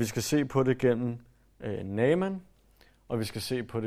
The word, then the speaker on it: Naaman: Jeg vil gerne lige starte med at sige, jeg tror, den rigtige udtale er Naaman. Jeg tror, eller jeg ved, at den apostrof, der er, gør, at man skal adskille det. Det er bare nemmere at sige Naaman. Så I må bære over med mig. Jeg kommer ikke til at udtale hans navn rigtigt. Men det er bare, så I ved, at der er en anden, mere hebraisk måde at udtale det på.